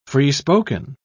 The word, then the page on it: free-spoken: British English